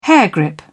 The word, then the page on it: hairgrip: British English